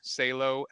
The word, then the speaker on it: Salo: That's my first name, Jack, middle initial R, Salo, Salo.